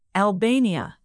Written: albania

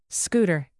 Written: scooter

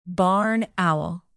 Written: barn owl

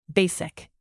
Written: basic

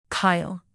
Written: kyle

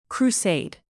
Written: crusade

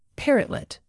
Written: parrotlet

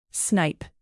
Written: snipe

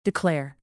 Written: declare

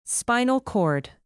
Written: spinal cord

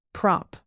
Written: prop

